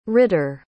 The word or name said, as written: ridder